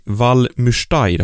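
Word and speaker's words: Val Müstair: Våran första tävling, den gick ju av stapeln i Valmürstein, har jag fått höra att det ska uttalas nu.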